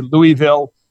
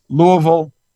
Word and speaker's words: Louisville: Now, there's a lot of confusion about how to pronounce our city's name. Some people say Louisville. It's Louisville. And so you're a Louisvillian.